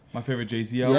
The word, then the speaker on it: Jay-Z: It's two syllables, man. It's Jay-Z.